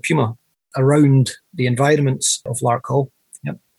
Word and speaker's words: Larkhill: Okay, Larkhill, is that how we're pronouncing this town in south-east of Glasgow, is that right? – Larkhill, yeah, Larkhill.